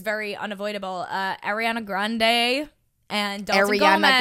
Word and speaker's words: Ariana Grande: It's Ariana Grande. Ariana Grande. – Oh. – That's how you say it. – Ariana Grande.